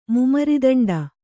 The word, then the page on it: ಮುಮರಿದಂಡ: mumaridaṇḍa